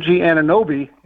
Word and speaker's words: Anunoby: OG Anunoby is the way I understand it to be pronounced.